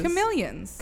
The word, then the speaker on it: chameleon: Chameleon. It's got a long E.